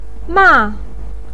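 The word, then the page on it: ma4: pinyin; tone 4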